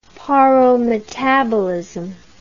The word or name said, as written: paurometabolism